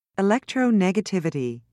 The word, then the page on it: electronegativity: British English